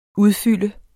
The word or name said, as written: udfylde